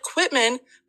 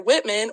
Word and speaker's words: Quitman: Whitman, which is spelt Quitman, but do not call it Quitman when you're in Whitman or they will be pissed. So you're pronouncing it with a W? – Yes.